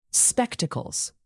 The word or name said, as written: spectacles